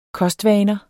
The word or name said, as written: kostvaner